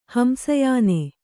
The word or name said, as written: ಹಂಸಯಾನೆ